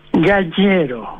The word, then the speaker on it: Gallero: Primera pregunta, ¿cómo se pronuncia bien en italiano su apellido, por favor? – Gallero. – Gallero, perfecto.